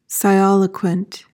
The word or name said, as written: sialoquent